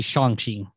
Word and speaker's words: Shang-Chi: But when I tell you that we all sat down on Friday and watched Shang-Chi? I think is how it's pronounced. – It's supposed to be Shang-Chi.